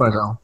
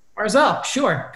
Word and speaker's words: Barzal: I think it's Barzal. – Barzal, sure.